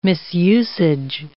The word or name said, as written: misusage